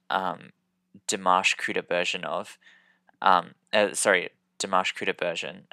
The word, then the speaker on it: Dimash: Dimash is spelled D-I-M-A-S-H.